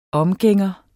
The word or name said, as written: omgænger